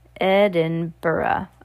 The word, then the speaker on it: Edinburgh: The city, which it turns out is pronounced Edinburgh. Oh, Edinburgh. Okay, I didn't know that either. Not Edinburgh. Interesting.